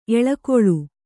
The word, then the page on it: ಎಳಕುಳಿ: eḷakuḷi